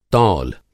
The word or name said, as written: तौल